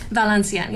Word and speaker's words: Valencienne: Valencienne, yes.